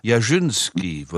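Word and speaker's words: Jarzyński: J-A-R-Z. Ja? Y-N-S-K-I. Und dann ist auf dem N noch ein kleiner Strich drauf. Jasinski würde man den Namen aussprechen in Polen.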